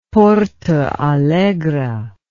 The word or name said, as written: Porto Alegre